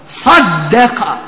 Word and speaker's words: صَدَّقَ: Riječ صَدَّقَ po svom izgovoru i za one koje još uzvišeni Allah nije počastio, a oni se još sa svoje strane nisu potrudili da nauče arapski jezik, ali mogu osjetiti u svojim ušima da ovdje ima nešto jače da se izgovara.